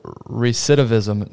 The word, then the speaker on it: recidivism: on your website says the national rates of recidivism.